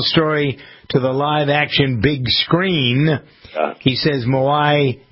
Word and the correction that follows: Maui: changed his life. Is that how you pronounce it? – Maui. – How do you pronounce it? – Maui. The island.